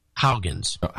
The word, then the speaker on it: Huygens: Well, tell me how to pronounce it. – Huygens. – Huygens.